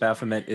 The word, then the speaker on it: Baphomet: Baphomet is the correct way of pronouncing that, yes.